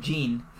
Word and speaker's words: Jinn: There's a band called Jean. It's spelled J-I-N-N.